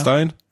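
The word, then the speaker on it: Steijn: Dann wird ihm von seinem selbst installierten Trainer, Stein wird er ausgesprochen? Stein? – Ja, Stein? – Stein würde ich sagen, ja.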